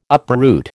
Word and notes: uproot: American English

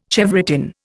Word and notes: chevrotain: Indian

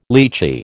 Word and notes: litchi: American English

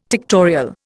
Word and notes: tectorial: Indian

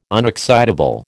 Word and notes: unexcitable: American English

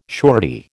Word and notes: surety: American English

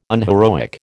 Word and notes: unheroic: American English

